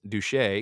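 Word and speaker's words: Duché: Adams then acknowledged that because he was from Boston, he was a stranger in Philadelphia, but had heard that the Reverend Mr. Jacob Duch, pronounced Duche, excuse me, yeah, Duche,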